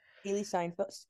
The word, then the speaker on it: Haley Steinfeld: Hailee Steinfeld, is that how you pronounce it? – Haley Steinfeld. Haley Steinfeld.